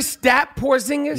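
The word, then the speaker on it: Kristaps Porziņģis: Kristaps Porzingis? – Yeah.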